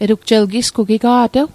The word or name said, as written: etugjel